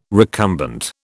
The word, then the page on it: recumbent: British English